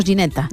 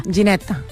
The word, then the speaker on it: Ginetta: Bien, ¿en italiano se dice igual? – Sí, decimos Ginetta. – Ginetta. Oh, qué nombre más bonito, ¿eh? – Es muy bonito. – Sí, sí. Es como gelato, pero Ginetta.